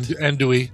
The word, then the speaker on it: Andouille: Anadoli sauce. Am I saying that right? – Andouille. – Andouille. – Like Andouille sausage, like it's in your jambalaya.